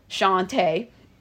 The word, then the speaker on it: Shawnta: Like my name, the way it's spelled, Shawnta, S-H-A-W-N-T-A, is not always pronounced the way it's spelled because people have to map or remember that when you write S-H-A-W-N-T-A, that is said Shawnta. Shawnta for Shawnta Barnes is spelled S-H-A-W-N-T-A.